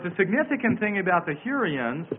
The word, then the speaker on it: Hurrians: called the Hurrians that occupied the city.